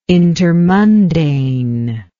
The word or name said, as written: intermundane